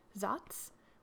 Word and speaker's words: Saaz: The traditional hops used in this beer are the four noble hops: Hallertau, Tettnang, Zatz, which is spelled S-A-A-Z, and Spalt.